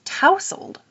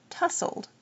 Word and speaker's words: tousled: And Carolyn on Twitter admitted that she learned that it's tousled from an audiobook, not tussled.